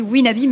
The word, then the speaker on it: Wenabi: Alors ça se dit Winabi, mais ça s'écrit W-E-N-A-B-I.